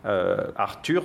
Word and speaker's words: Arthur: Et on nous dit « Caer keni beif Arthur » Arthur, donc le U se prononce I en gallois.